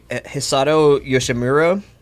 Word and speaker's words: Hisato Yoshimura: An army engineer named Hisato Yoshimura conducted. Did I say that right? – Yes.